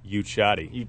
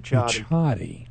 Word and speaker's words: Yewchati: Yewchati. – Yewchati.